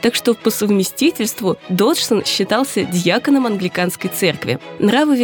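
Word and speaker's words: Dodgson: Свою настоящую фамилию математик не очень любил, настаивал, что правильно она произносится Доджсон.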